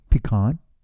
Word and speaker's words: pecan: How do you say pecan? – Pecan. – Is that how you say it? – Yeah. – Is that the right way to say it? – Well, you pecan say it any way you want. – I think you would be wrong if you said it any other way.